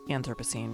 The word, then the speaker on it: Anthropocene: And I've heard Anthropocene, I say Anthropocene, but I also come from the American South, so I put the emphasis on the first syllable almost all the time.